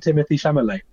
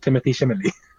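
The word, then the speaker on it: Timothée Chalamet: I think he's actually pronounced Timothy Chalamet.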